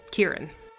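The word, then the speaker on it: Ciarán: Kieran Hines' first name is pronounced Kieran.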